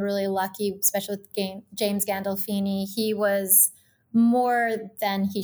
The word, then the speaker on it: Gandolfini: I think about Emilio Estevez on the Mighty Ducks now, and of course, you working with James Gandolfino and have I said it right? Gandolfini? – Gandolfini. Yeah, you almost Gandolfini. I knew it was wrong as soon as I said it.